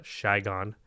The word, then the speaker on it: Saigon: also known as Saigon, or as the locals call it, Shigon. – Oh, really? Shigon. – That's how they pronounce it.